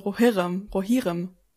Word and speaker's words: Rohirrim: Die Betonung auf dem O, ob es das erste ist, das betont wird, oder das zweite. – Also Rohirrim.